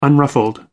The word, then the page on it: unruffled: American English